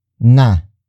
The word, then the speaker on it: na: The next word is na.